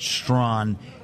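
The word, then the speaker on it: Strachan: Yes, it's pronounced Strawn. It's not spelled like that, but, you know, who's keeping score?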